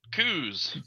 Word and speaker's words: Coos: And how do you say your last name? – Coos. – Coos with a C on it.